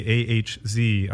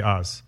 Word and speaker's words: AHZ: I did say AHZ on my Instagram, but it is actually Oz.